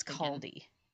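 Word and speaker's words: Kaldi: But how's it spelled? Is it K-H? – No, it's K-A-L-D-I. – Oh, okay. – So I think it's Caldy.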